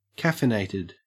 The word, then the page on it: caffeinated: Australian English